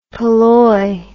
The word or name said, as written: polloi